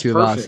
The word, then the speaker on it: Tuivasa: That's Tuivasa.